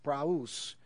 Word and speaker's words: praus: So if you consult a Greek lexicon, you'll find out with a little study that the Greek word we translate as meek, which is pronounced praus, means more than meek.